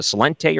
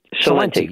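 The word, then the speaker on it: Celente: Is it Salente or Salenti? – Salenti. – Salenti, yeah.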